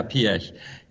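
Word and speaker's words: Piëch: Yeah, Piech.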